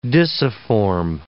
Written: disciform

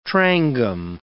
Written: trangam

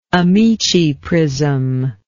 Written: amiciprism

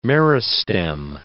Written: meristem

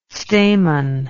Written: stamen

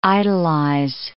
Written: idolize

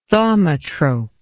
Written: thaumatrope

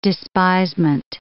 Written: despisement

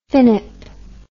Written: finnip

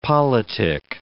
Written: politick

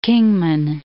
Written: Kingman

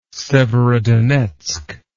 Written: Severodonetsk

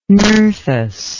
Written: Nerthus

